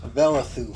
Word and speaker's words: Velithu: Velithu. – Velithu. – Velithue. – Neat. – The last E is silent.